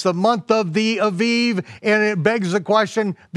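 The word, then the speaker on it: Aviv (אביב): Now, in your King James Version, it'll say the month of Abib. Okay, I don't care whether you pronounce it with the with the, with the vet or the bat.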